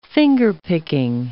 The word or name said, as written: fingerpicking